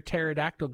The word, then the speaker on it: pterodactyl: Why can't you hear a pterodactyl going to the bathroom? – I don't know. – Because the P is silent. Pterodactyl spelled P-T-E-R. – Keep going. – Oh, D-A-C-T-L.